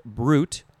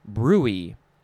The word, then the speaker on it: bruit: So it is spelled B-R-U-I-T. Number one definition says it's pronounced brute. And then the number two definition says it's pronounced bruy, bruy.